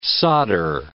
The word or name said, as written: solder